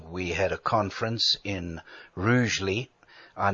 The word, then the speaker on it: Rugeley: And then the first night we were staying in Stoke-on-Trent at a B&B, and our host put us straight and said it's pronounced Rugeley.